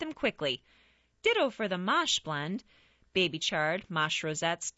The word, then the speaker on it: mâche: Mâche, pronounced mâche, is an heirloom green prized for centuries by Europeans for its fresh, nutty flavor.